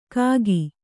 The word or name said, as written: ಖಾಖಿ